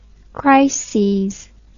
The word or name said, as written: chryses